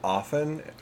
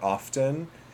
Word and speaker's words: often: Which, just a quick point on the word often or often, is there is debate within English literature, and I mean English literature in terms of, like, English as a language, about whether or not to pronounce the T.